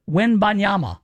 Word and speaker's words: Wembanyama: But now it's mostly Victor Wembanyama. – Yes. – That's his name? That's how you say it? – Yes.